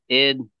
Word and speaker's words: ID: It's pronounced id as one word, obelus. – It Obelus? Okay. – It Obelus, yeah. It's not an act. Well, it was an acronym, but it's always been pronounced id.